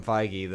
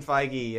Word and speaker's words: Feige: Man, is that how you say that name? – Feige? – Yeah. – Yeah. – I have been saying that wrong. – What are you saying? Like Feige or something? – No, I was like Kevin Feig.